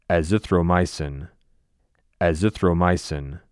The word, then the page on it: Azithromycin: az ith roe MYE sin